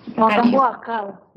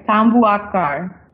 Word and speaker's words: Tambuakar: Is it Tambuakar? – Tambuakar. – Yeah. So it sounds like you were close, right? Say it again, guys. – Tambuakar. – So very close, A+.